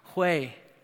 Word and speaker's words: Hui: And she spelled H-U-I, and she said, "It's actually pronounced Hue."